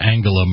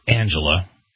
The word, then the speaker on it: Angela: Mr. Obama made his remarks at a news conference in Berlin beside the German Chancellor Angela Merkel. How do you pronounce that? It's not Angela. – I think it's Angela Merkel. – It's a hard G, but most people do say Angela.